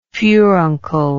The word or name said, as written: furunculous